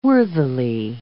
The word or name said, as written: worthily